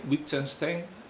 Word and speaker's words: Wittgenstein: Wittgenstein, ¿lo he dicho bien? – Sí, lo he dicho bien.